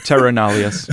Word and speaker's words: Terra Nullius: Nullius, and Nullius is just how you spell it, N-U-L-L-I-U-S. – Perfect. So for the Americans, terror. – Yeah. Is that how you say it? – No, we would say like terror. – Okay, cool. – Yeah. Terror nullius.